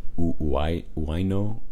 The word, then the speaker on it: Huayno: She sings it's a Huayno singer. I don't know. I don't know how to pronounce it. H-U-A. – H-U-A. – Y-N-O.